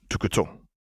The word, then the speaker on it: Tukutu: That doesn't surprise me in the slightest, and it's pronounced Tukutu, Lux said with a small laugh. Say it like you accidentally glued the back of your tongue to the roof of your mouth.